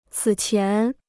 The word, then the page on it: 此前: cǐ qián